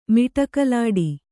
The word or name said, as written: ಮಿಟಕಲಾಡಿ